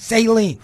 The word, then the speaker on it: Saline: Put on by Jennifer Lancaster, her group actually, the uh, Saline, and that's how I pronounce it, Saline.